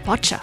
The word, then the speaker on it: Bacha: It's Bacha, like robots dancing the cha-cha.